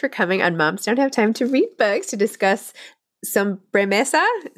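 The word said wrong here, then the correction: Sobremesa: Did I say that right? – Yeah, Sobre Mesa, perfect.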